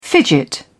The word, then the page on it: fidget: British English